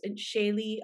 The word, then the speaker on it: Shaylee: So it's my name's Shelly, but it's Shaylee Anand is like how it's pronounced in Hindi.